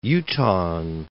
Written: Utahan

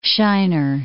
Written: Shinar

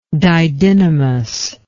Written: didynamy

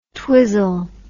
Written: twizzle

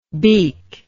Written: beek

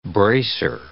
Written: bracer

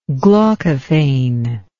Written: glaucophane

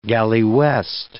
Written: galley-west

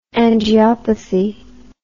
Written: angiopathy

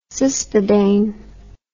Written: cispadane

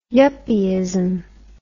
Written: yuppieism

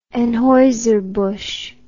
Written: Anheuser-Busch

